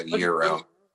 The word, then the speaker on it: gyro: I had a gyro.